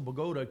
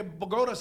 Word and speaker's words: Bogota: Se escribe igual. – Yo vivo cerca de Bogota. – Pero se pronuncia diferente, Bogota. Aquí, cuando yo llegué aquí, brother, yo no sabía... Yo decía, ¿dónde quedará eso, Bogota?